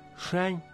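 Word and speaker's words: Шань (山): Шань – это гора. Произносится в первом тоне.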